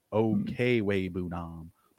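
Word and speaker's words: Okwuegbunam: Dude, any football fans out there that play fantasy hockey, like Albert Okubanam is nothing to this guy's name. Like, and even I think I just said that wrong. It's Okwebunam.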